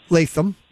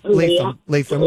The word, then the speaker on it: Lapham: So, tell us a little bit about Lapham Farm Village, which is pronounced Latham or Latham. – Latham.